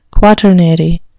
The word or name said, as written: quaternary